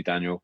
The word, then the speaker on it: Daniell: Just I think like Instagram and Twitter is just at Andy Daniell, with a surname spelled with two L's, which is actually how it's spelled.